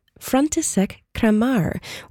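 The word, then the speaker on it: František Kramář: Franz Krommer, K-R-O-M-M-E-R. It's pronounced differently as well in the Czech version of his name, František Kramar, or K-R-A-M-A with an accent, R.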